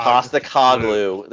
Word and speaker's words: Postecoglou: Pasta Coglu.